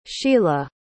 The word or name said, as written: sheela